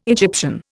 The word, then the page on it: Egyptian: Indian